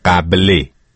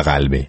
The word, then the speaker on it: قبلی: بله، قبلی، قبلی نه قلبی.